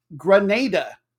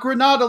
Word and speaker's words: Grenada: Grenada. Yes, not Grenada like the Spanish city, Grenada. We want to make sure we get the island country pronunciation right.